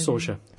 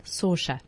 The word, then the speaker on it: Saoirse: Hannah with, I don't know how you say it, Saoirse Ronan or Saoirse Ronan? – Saoirse. – Saoirse. There we go. – It's easy. S-A-I-O-R-S-E.